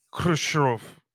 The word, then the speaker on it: Khrushchev: Khrushchev. Yeah, it's just forget it. There's a ha in Russian. There's actually a letter that's ha, and Americans don't know how to say it, so forget it.